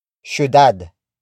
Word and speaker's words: syudad: For example, makulay ang syudad. Makulay ang syudad. Makulay ang syudad. It means the city is colorful. This sentence is in PF format. Word for word it's makulay ang syudad.